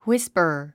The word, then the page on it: whisper: hwíspər; ウィスパァ